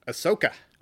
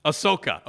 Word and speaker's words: Ahsoka: Ahsoka. – Ahsoka.